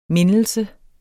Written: MINDELSERNE

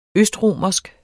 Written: østromersk

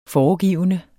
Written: foregivende

